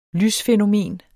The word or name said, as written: lysfænomen